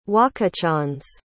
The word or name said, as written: Wakichonze